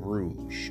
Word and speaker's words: Rouge: I recorded this review and I kept pronouncing the title of this album as Rogue and not Rouge, okay? You know, Rogue and Rouge are similarly spelled, so it was easy to mess that up, but I should have known it was called Rouge.